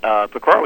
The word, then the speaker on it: Pecora: Your publisher, Random House, notes that in the book, you call you close with a call for a version of the Pecora Commission. – Yep. – Is that Pecora.